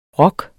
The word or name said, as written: ROKKEN